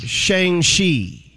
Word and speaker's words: Shang-Chi: They're saying it's pronounced Shang-Chi.